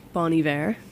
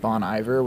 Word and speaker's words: Bon Iver: Bon Iver. – Interesting. – Thoughts, Reese? – I have historically said Bon Iver, which I don't think is right.